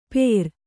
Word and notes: ಪೇರ್: Kannada

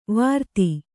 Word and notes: ವೃತ್ತಿ: Kannada

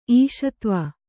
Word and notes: ಈಶತ್ವ: Kannada

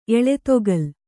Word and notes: ಎಳೆತೊಗಲ್: Kannada

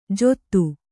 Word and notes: ಜೊತ್ತು: kannada

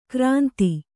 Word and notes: ಕೋರಿಂದೆ: kōrinde

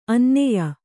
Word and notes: ಅನನ್ಯ: Kannada

